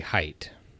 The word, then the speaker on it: Haidt: I uh, would have said hate because I mispronounce everything, but um, I think I saw on his own website that you say height.